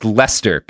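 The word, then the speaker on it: Leicester: I'm sorry I didn't realize it was pronounced Lester, because it's not at all spelled like Lester.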